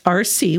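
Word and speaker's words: RSEE: The tentative name being used is the RC, that's R-S-E-E, school, pronounced RC, which stands for Richland Small School Educational Environment, focusing on the smaller class size opportunities for students.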